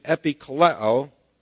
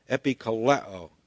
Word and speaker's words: epikaleo: And the Greek word rendered appeal there in verse 11 is epikaleo, spelled E-P-I-K-A-L-E long O, epikaleo.